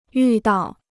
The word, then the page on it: 遇到: yù dào